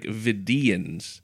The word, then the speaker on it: Vidians: Or I feel like the emphasis is more, it's like Vidians. The emphasis is more on that second syllable.